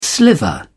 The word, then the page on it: sliver: British English